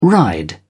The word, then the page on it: ride: British English